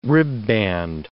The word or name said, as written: ribband